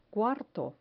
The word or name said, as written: cuarto